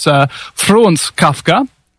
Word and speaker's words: Kafka: And in actual fact, it's Franz Kafka, the German pronunciation is Franz Kafka, on the 3rd of July 1883 to the 3rd of June 1924 was his lifespan, was a culturally influential German novelist.